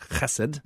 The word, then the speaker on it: Chesed: And in Hesed House, if you're not familiar with the term Hesed, it's a Hebrew term. It's supposed to be pronounced Chesed.